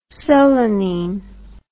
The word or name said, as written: solanine